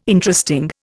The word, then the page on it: Interesting: Indian